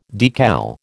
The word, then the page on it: decal: American English